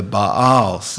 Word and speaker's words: Baal: Then the Israelites did evil in the eyes of the Lord and served the Baals, is the way you're supposed to pronounce Baal.